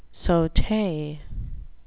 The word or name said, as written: saute